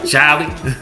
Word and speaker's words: Charlie: Like, y'all ain't even saying it right. There's no R in Charlie. – What's up, Charlie?